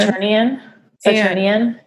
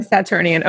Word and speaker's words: Saturnian: And he's like a very Saturn, how do you say it? Like Saturn person. – Saturnian. He's a Saturnian person. – Saturnian.